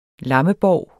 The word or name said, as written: lammebov